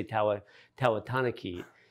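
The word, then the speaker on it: Tavatanakit: They were saying Tawatanakit on TV, but my wife is Thai, and she would say Tawatanakit.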